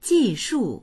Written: ji4shu4

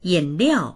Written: yin3liao4

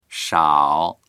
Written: shao3

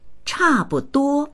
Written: cha4buduo1

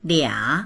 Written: lia3

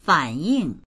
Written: fan3ying4

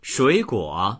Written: shui3guo3

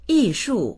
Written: yi4shu4

